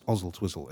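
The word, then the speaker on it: Oswaldtwistle: But it's spelled Oswald Twizzle. But it's pronounced Ozzle Twizzle, as far as memory serves.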